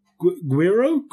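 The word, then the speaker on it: güiro: Guiro, Spanish pronunciation. Guiro? – Guiro. – That's good. – Guiro. – I wasn't far off. – Okay, I was when I said gyro.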